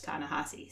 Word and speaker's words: Ta-Nehisi: But I once heard him talking on the radio about the fact that his first name is pronounced Ta-Nehisi, so I am confident.